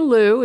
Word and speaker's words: Lieu: My name is Barbara Liu. It's spelled L-I-E-U. It's in lieu of my old name or in lieu of being straight.